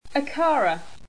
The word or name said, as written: acara